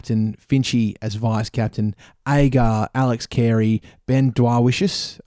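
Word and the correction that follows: Dwarshuis: I can't. Have I said that right? – Dorsius, thank you.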